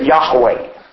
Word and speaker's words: Yahweh: And I always, and have customarily done so for years, when I come across this particular spelling of this particular word, I always pronounce it Yahweh, which is Y-H-W-H.